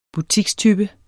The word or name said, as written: butikstype